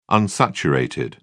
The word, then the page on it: unsaturated: British English